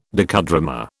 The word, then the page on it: docudrama: British English